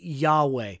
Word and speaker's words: Yahweh: That is pronounced, most scholars understand, to be Yahweh.